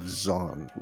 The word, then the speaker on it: Dzyan: The cover reads The Stanzas of Zaun, which is spelled D-Z-Y-A-N.